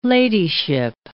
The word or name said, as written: ladyship